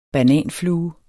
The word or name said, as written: bananflue